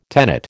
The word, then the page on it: tenet: American English